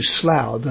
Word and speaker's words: Slough: The name of that town is spelt S-L-O-U-G-H and pronounced Slough.